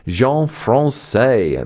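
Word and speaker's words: Jean Françaix: In fact, this composer is so French that his name sounds like the word for French. Jean Francais, the divertissement for bassoon and strings, is played by the Gaudier Ensemble.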